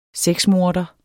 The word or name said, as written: sexmorder